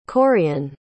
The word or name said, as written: Corean